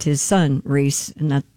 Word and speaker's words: Rhys: Does he pronounce it Reese? – Reese. – Does he? I don't know. – Oh, it is Reese.